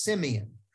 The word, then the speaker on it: Simeon: It actually should be pronounced Simeon.